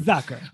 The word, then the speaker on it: Zucker: Zucker.